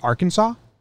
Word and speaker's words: Arkansas: You put A and R in front of Kansas, but it's pronounced Arkansas? – It's a tough language.